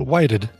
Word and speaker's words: Whited: Sean Whited? Witted? How would you say that? Not Whitehead. – Sean... – Witted? – Sorry. Oh, Whited. Yeah, I'd say Whited.